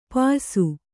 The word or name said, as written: ಪಯಸ್ಸು